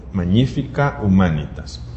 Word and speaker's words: Magnifica Humanitas: Entonces el Papa León 14 publica Magnifica Humanitas, la pronunciación debería ser así porque el latín no suele editar la sílaba tónica, es la segunda, Magnífica Humanitas. Entonces, nosotros estábamos esperando a ver el primer documento que publica un papa, es el documento programático.